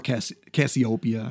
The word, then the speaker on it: Cassiopeia: Inside the house of Aries, we have Cassiopeia is one of the constellations we're going to talk about. – Okay. – Or Cassiopeia, I guess is some pronounce it.